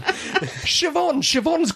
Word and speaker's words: Siobhan: Siobhan's got a B in it. But you don't pronounce the B, do you? – Yes, but all those letters are in it. – But you do not pronounce the B. There is no B.